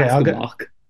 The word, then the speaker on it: Aschenbach: I'm saying Aschenbach. It's probably Askenbach or okay, Askenbach. I don't know.